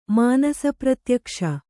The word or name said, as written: ಮಾನಸಪ್ರತ್ಯಕ್ಷ